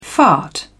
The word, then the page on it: fart: British English